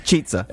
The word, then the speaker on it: Chizza: It's spelled Chizza, yeah. Cheeza. – Uh, C H I Z Z A. It's pronounced Cheeza.